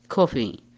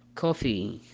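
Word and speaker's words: coffee: الإي يكتب في الأخير لكنه لا ينطق، لكن متى ننطق حرف الإي؟ إذا تكرر الحرف أي صار حرف دبل إي. مثال عليه كلمة كوفي، كوفي.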